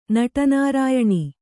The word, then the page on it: ನಟನಾರಾಯಣಿ: kannada